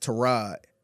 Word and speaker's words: Tyrod: And he said it's pronounced Tyrod. It's like, why the fuck did you ain't say that shit 5 years ago? – Yes.